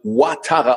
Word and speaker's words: Ouattara: Äh, ja, mein Name wird Ouattara ausgesprochen. Das ist nämlich äh, aus der Elfenbeinküste. Französisch ist OU, ist okay. dann Wa, ähm, aber wird meistens in Deutschland immer mit wie als Q gedacht, so Quatara.